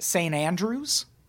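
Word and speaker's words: St Andrews: It was that year held at St. Andrews, which is a super historic golf course. If you're unfamiliar, golf invented in Scotland. And St. Andrews is a golf course in Scotland. – It's pronounced Standrews. I'm reading it.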